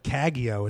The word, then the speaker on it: Cagaeo: Is it Cagaeo? – Cagaeo. It's a hard G.